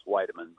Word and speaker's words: Wiedeman: By the way, my last name is pronounced Wiedeman, but I've been dealing with that all my life.